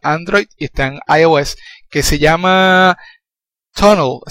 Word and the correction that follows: TuneIn: así que se pronuncia. – No, TuneIn Radio.